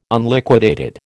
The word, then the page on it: Unliquidated: American English